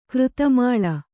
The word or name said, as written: ಕೃತಮಾಲ